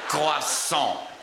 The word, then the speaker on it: Croissant: Ja, jetzt ist Croissant. Ist ja die korrekte französische Aussprache, das ist ja an für sich uninteressant.